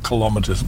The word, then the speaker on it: kilometres: Notice it's kilometres, not kilometres, by the way. The correct pronunciation is kilometres. Get over it.